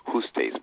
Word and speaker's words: Justiz: Now it's spelled with the Latin spelling J-U-S-T-I-Z. Just who is actually pronounced Justice, but you know Justice.